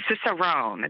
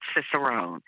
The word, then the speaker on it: Cicerone: And my personal page now reflects Cicerone. That's how you pronounce it. It's Cicerone. Unless you're in Italy, and it's harder for me because I'm not Italian.